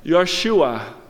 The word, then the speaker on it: Yeshua: But Jesus is a Jewish name, which would sound something like Yeshua or Yeshua.